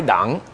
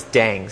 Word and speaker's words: Dang: Well, you know, my name is a Chinese last name, so true pronunciation is Tai Dang, but everyone's Dang, so Tai Dang. So I'm fine with that too.